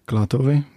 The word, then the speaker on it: Klatovy: Wie spricht man es aus? – Klatovy, ja. – Ja, fast richtig.